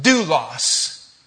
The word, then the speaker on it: doulos: It's pronounced doulos.